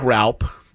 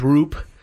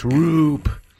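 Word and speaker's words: Roupp: Looks like Raup, but it's pronounced Roop. That's not boo, they're saying. That's Roop. He'll be pitching for the Giants.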